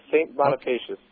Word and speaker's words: St. Bonifacius: Okay, so it's St. Bonifacius. Is that how you pronounce it? – Yes, St. Bonifacius.